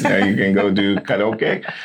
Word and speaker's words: karaoke: in Hawaii, and my buddy was like, no, it's pronounced karaoke. – Yeah, it's karaoke.